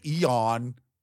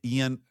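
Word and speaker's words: Ion: It's Ian Hazakostas. – Ian? – Yeah. – That's how you say it. How would you say it otherwise? – People say Eon. – Eon? – Eon. Like, I've heard everything.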